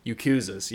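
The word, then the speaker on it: yakuza: Yakuzas? – Yakuzas, yeah.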